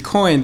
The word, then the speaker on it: Quoine: And so people know it's Coin. It's spelled Q-U-O-I-N-E.